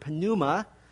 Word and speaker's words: pneuma: P-N-E-U-M-A-T-O-L-O-G-Y. That is the study of the Holy Spirit from the Greek word pneuma, which just means air or wind or breath or spirit. We don't pronounce the p sound in English.